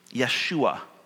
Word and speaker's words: Yeshua: Yeshua is the Hebrew pronunciation for his name.